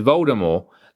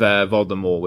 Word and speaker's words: Voldemort: So this actually caused the, this little thing here with Voldemort with the silent T, this actually caused the entire audiobook in America to be re-recorded because they mispronounced it.